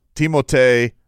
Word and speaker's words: Timotej: Spell it. T-I-M? – T-I-M-O-T-E-J. Timotej Malovich. Malovich? – T-H must mean something. Like, it must be a different pronunciation. – Oh, you're making it worse. – Or is it just, or is it just silent? Timotej Malovich. – I think it's Timotej. I think you got it right with Timotej.